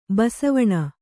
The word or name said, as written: ಬಾಸೆವೆಣ್